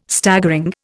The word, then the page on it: Staggering: Indian